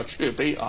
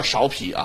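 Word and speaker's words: 芍陂: 淮南现在叫安丰堂啊，它过去的名字叫雀碑啊，不叫苕皮啊，古语发音啊叫雀碑啊。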